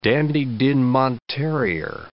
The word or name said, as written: Dandie Dinmont terrier